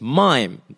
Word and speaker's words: Maim: Like, no, my name is Maim. It's spelled M A I M, which is, you know, maim, the word, the verb to maim.